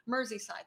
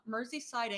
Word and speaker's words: Merseyside: This is in Merseyside. That's it. Sorry, I pronounced it wrong. Merseyside, England.